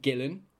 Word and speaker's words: Guillen: Most people call me Guillen, right? – Yeah.